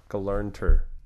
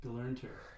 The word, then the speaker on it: Gelernter: David Gelemter. Gelemter. – That's not an M, that's an R. Gelernter. – Gelernter.